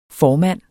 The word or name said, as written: formand